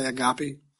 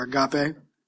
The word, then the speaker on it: agape: Actually, with a Greek tongue, I think it's pronounced agapa, but, me and my country accent, we're gonna say agape, or agape.